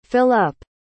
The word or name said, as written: fill-up